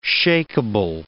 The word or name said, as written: shakeable